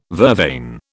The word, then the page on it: vervain: British English